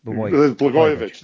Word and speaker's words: Blagojevich: Blagojevich.